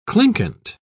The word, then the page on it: clinquant: British English